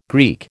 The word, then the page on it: Greek: American English